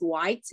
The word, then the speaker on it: White: Last name is White, W H I T E.